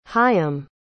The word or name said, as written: hayyim